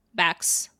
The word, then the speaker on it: backs: What's the plural of that? Backs. Backs. Sk as like a snake, right?